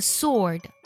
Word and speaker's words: sword: 比如说剑这个单词 ,sword, 字母 w 是不发音的，它读作 sword, 而不是 sword。